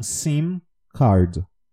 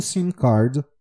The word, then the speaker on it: SIM card: Com M, tá? Toda vez que a gente tem um M, é importante que a gente pronuncie a palavra com o M fechado no fim da palavra. Então, SIM card.